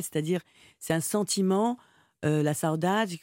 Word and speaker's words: saudade: Oui, la saudade.